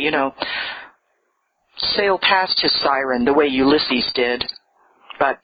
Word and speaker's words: Cyryn: Her name, and I'm not making this up, was Siren, spelled C-Y-R-Y-N, but pronounced Siren.